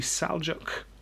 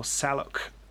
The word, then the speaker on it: Seljuk: The Seljuk or Saluk Turks, depending on which way you pronounce that.